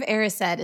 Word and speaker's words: Erised: The mirror of Erised. Is that how that's pronounced? – Erised, yeah. Because right? It's desire is the last word. Desire is backwards.